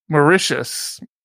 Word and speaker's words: Mauritius: How do you say this? Mauritius.